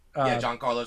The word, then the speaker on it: Giancarlo: Giancarlo, I say it right? – Giancarlo Stanton.